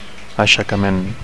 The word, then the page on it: aixecament: Catalan